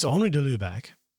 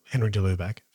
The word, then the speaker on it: Henri de Lubac: It's Henri de Lubac, or Henry de Lubac, for those that are, insist on the Australian pronunciation.